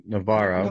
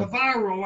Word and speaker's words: Navarro: Navarro. – Navarro, I like to say Navarro in her kangaroo court because it rhymes.